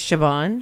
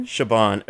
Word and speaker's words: Siobhan: Siobhan, but it's Siobhan. – Siobhan. Okay. Siobhan.